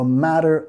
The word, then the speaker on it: matter: Matter, okay, enfatizando en la primera sílaba, nota la R suave, matter, no estoy diciendo matter, es matter, matter, matter of opinion, matter of opinion.